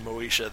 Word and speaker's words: Moses: The actual Hebrew pronunciation of Moses is a little bit closer to Moesha than Moses.